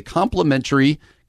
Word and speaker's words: complimentary: Uh, the new year is underway, and our friends at In Touch Ministries want to bless you with a complimentary, complimentary, complimentary, complimentary Hmm. Yeah, I think that's a T sound. I would add one more syllable. Complimentary. That's you added one emphasis.